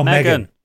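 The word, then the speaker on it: M3GAN: Think Age of Ultron, Terminator, The Matrix, M3 GAN, etc. M3 GAN? – Yeah. – Megan. – No, Megan. He's written it phonetically here.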